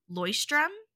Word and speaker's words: Leuchtturm: You've got – I had to look up the pronunciation, but it's Leuchtturm 1917, which is the very like – it's like a sturdier, I think, more thicker paper than the Moleskine.